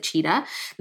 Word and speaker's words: Tshida: If you're going to research this book, the author's last name is actually T-S-H-I-D-A, but she pronounces it Cheetah.